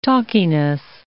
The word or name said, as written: talkiness